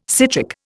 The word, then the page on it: citric: Indian